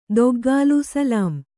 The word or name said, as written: ದೊಗ್ಗಾಲು